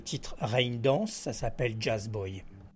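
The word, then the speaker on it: Jazzboy: Jazz Boy, ça s'écrit Jazz Boy, JAZZBOYS, mais ça se prononce à l'anglaise, paraît-il.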